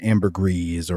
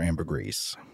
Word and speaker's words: ambergris: English, it seems everybody says the like a soft pronunciation of the S. So it's like ambergris or ambergris. – I feel like I'm going to be saying ambergris because I can't look at the word G-R-I-S and pronounce the S, even though I don't know French.